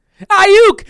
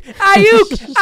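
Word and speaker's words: Aiyuk: Say it right. Ayuk! Ayuk! Ayuk!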